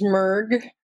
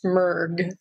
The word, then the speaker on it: Mrg: My name is Merg Simon, and it's just three letters, M-R-G, and it's pronounced Merg. So you aren't mishearing anything, and Kirsty's not mispronouncing anything. It really is Merg.